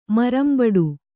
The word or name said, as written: ಮರಂಬಡು